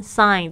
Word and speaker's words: sign: 这边我们特别注意一下，sign s i g n sign 这个 g 没有发音哦。